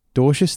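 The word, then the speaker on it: Dwarshuis: So the Aussie squad is David Warner captain, Finchy as vice captain, Agar, Alex Carey, Ben Dwawishus. I can't. Have I said that right? – Dorsius, thank you.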